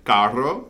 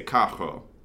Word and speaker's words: carro: And in Puerto Rico, it's a relatively common pronunciation to not roll your R's and to instead say R's like a H sound. So instead of carro, they'd say cajo, right?